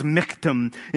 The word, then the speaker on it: michtam: It looks like michtam. But it's actually, the way you pronounce it is michtam.